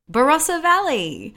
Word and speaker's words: Barossa: Is it Barossa Valley? – Barossa Valley.